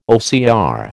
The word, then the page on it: OCR: American English